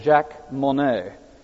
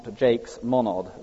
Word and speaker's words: Jacques Monod: Now, Dawkins, interesting, I didn't realize this, but I think he leans very heavily on a chap called Jacques Monod, not Jakes Monod, as I'm told some pronounce him.